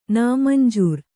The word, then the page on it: ನಾಮಂಜೂರು: kannada